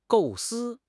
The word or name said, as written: 构思